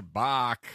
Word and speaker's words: Bach: Yeah, it was B-A-C-H is yep. what he said there. Bach. – That's pronounced Bach.